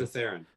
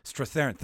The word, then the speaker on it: Strathairn: With Ron Perlman, Cooper, Defoe, Stram? I never pronounce his name correctly. David Strathairn. – Strathairn. Thank you.